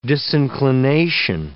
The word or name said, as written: disinclination